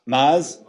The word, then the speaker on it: Maz: Should we throw Maze in there? Maz, how do you say? – I liked Maz a lot. – Maz. – I can never get her name right. Maz. Thank you, from the other room.